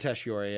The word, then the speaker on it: Tatasciore: Fred Tatasciore, yeah.